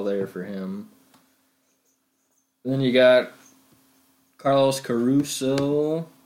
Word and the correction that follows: Carrasco: I don't know how you say his last name. – Carrasco? – Carrasco, yeah.